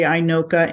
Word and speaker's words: INOCA: In the English language, we say INOCA and MINOCA.